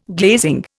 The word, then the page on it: glazing: Indian